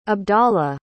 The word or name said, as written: abdallah